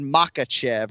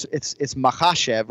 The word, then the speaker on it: Makhachev: Well, he kind of said Makhachev or whatever. It's Makhachev, right? So you're emphasizing the second syllable, and that'll be our broadcast focus this weekend.